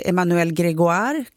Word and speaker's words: Emmanuel Grégoire: Men i Paris finns i alla fall en borgmästare som heter Emmanuel Grégoire. Kanske är lite osäker på uttalet där. – Det lät korrekt.